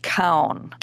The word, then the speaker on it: Keown: And it is written by Dr. Daniel, I believe the last name is pronounced Kown, K-E-O-W-N.